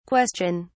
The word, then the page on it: question!: British English